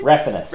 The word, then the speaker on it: Refenes: Refenes. – Refenes. My apologies. – Yeah, you got to pronounce it the most white way possible.